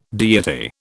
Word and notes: deity: British English

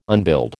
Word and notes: unbuild: American English

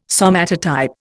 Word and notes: somatotype: Indian

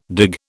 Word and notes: dig: British English

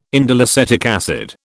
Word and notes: indoleacetic acid: British English

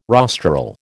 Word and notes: rostral: American English

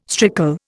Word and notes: strickle: Indian

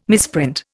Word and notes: misprint: Indian

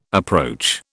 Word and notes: approach: British English

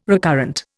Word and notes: recurrent: Indian

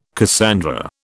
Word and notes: Cassandra: British English

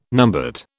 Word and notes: numbat: British English

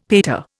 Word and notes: pater: Indian

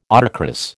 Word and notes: autocross: American English

